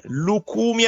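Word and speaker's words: Lucumí: Lukumi ha giocato in Belgio ed è sposato con una donna belga.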